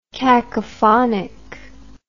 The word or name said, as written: cacophonically